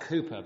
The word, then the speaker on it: Cowper: He's actually pronounced Cooper, but we all say Cowper because that's how it's spelt.